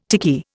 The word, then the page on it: tiki: Indian